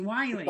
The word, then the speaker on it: Wiley: Yes, it's Dr. Nancy Wiley.